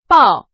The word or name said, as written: 报